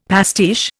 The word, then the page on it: pastiche: Indian